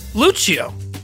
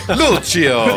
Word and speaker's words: Luciow: Let's start from Stuart, and then how would you pronounce that name, do you think? – Lucio. – Yeah, I think he's right. – Lucio. – L-U-C-I-O-W. It's the W that throws me.